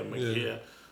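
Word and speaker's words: Ma'Khia: It's Micaiah. It was Micaiah Bryant. – Micaiah or Makia. – Yeah, Makia. Yeah.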